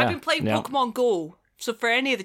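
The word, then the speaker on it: Pokémon Go: I believe it's pronounced Pokemon Go.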